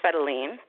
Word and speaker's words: Fedeline: Am I saying your name right, first of all? – It's pronounced Fedeline, but thank you for asking.